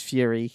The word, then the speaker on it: Furie: I think maybe it's Fury, but it's spelled F-U-R-I-E. – I would pronounce that as Fury.